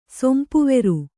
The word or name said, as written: ಸೊಂಪುವೆರು